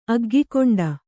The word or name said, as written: ಅಗ್ಗಿಗೊಂಡ